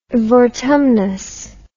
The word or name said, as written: vortumnus